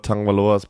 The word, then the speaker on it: Tagovailoa: Ja, Tagovailoa, wir hatten ja wieder das Problem, dass Leute dann sagen, warum sprecht ihr ihn denn mit einem N aus? Hat er selber gesagt, so wird er ausgesprochen.